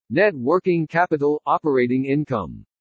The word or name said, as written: NET WORKING CAPITAL-OPERATING INCOME